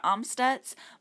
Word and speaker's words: Amstutz: You can find all of my social media accounts at Sandra Amstutz. My last name is spelled AMS. T-U-T-Z.